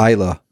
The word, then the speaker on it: Islay: It's actually pronounced Islay. It's spelt Islay, but the island of Islay, which the whiskey you're drinking is from, and I'll be visiting their distillery.